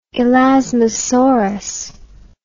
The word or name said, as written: elasmosaurus